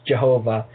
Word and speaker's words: Jehovah: And that, first of all, that J in Jehovah is actually a Y in Hebrew and an I in Greek. Okay? So, J is mostly in the Greek, in the English language now. It's pronounced Jehovah in our language.